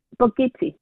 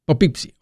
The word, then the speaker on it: Poughkeepsie: Poughkeepsie. Poughkeepsie.